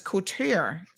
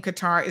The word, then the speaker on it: Qatar: Okay, other Arabic speakers have explained that the English word closest to the native pronunciation might actually be guitar. In Gulf dialects, the first consonant in Qatar is more like a G than a hard C. Okay, so guitar? Guitar? What? But the proper pronunciation, the one that will roll off of local tongues through the World Cup, cannot be spelled out with a Latin alphabet.